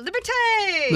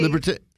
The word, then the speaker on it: Liberté: Brasserie Liberté opened up in Georgetown. – I feel like you're saying it wrong. – And they open. – No, Liberté. – Liberté.